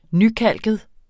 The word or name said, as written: nykalket